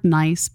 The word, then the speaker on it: Nice: It's spelled like the word nice, but it's pronounced like the city in France, Nice.